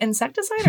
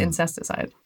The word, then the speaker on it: Incesticide: Like, is it Insecticide or is it Incesticide? – I think Insecticide, but I am not the pronunciation. – I have been saying Incesticide. I just want you to know. Really? Today years old. – Incesticide is a very different image.